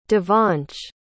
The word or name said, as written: Devansh